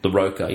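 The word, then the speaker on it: Larroca: Iron Man by Fraction and LaCora, or LaCara, I'm sure how you say his name. – LaRocca, yeah.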